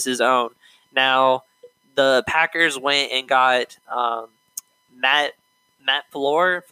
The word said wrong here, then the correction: LaFleur: Floor, how you say his last name? Matt Floor, I think is how you say it. For the Packers, they hired their Matt LaFleur. – Yeah, LaFleur. There you go.